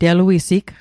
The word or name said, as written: teluisig